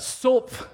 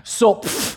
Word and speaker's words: Zopf: The spelling is Z-O-P-F. Say it with me. Yeah, how do you say that? Sulp, I think, is how they say it. It's almost like you have to spit at the end. Sulp.